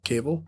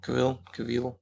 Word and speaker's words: Cavill: He has a new film coming out, I think, with Henry Cavill. Cable? – Cavill.